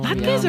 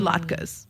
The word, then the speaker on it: latkes: Oh, latkes or latkes? What's the correct way of pronouncing it? – They're both acceptable.